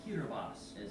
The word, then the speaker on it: Kiribati: Half a world away and surrounded by the Pacific Ocean are 33 picturesque islands which make up the Republic of Kiribati, or Kiribati, as locals say.